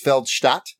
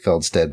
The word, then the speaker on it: Felsted: It's pronounced Feldstadt, but I'll give you... It looks like Feldsted, but in German, it's Feldstadt.